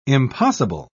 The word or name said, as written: impossible